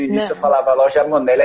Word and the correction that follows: Moneli: aí depois eu percebi, falei, putz, cara, tô falando errado, é Moneli.